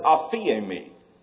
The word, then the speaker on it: aphiemi: That Greek word to forgive, in the Greek we pronounce it aphiemi. Doesn't even sound like it.